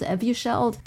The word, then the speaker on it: Evusheld: Is it Evusheld? Evusheld? People have to look it up because I think I'm butchering. I might be butchering the name. – That's how I've been pronouncing it.